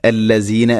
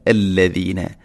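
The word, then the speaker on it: الذين: ഇന്ന അല്ലദീന, ദാലിൻ്റെ ഉച്ചാരണമാണ് ശ്രദ്ധിക്കേണ്ടത്. അല്ലസീന അല്ല അല്ലദീന, അല്ലദീന.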